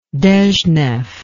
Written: dezhnev

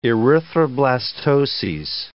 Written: erythroblastoses

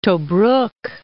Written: Tobruk

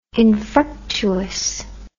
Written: infructuous

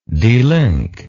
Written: delink

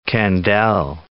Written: Kandel

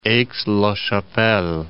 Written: Aix-la-Chapelle

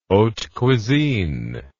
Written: haute cuisine

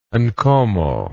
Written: nkomo

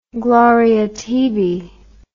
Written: Gloria tibi

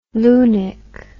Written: lunik

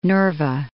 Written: Nerva

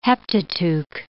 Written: Heptateuch

